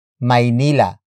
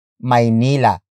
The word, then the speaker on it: Maynila: And that place is Maynila. Maynila. Maynila. Maynila is spelled as capital M-A-Y-N-I-L-A. It either refers to the city of Manila or Metro Manila, the national capital region of the Philippines.